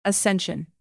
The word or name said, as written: ascension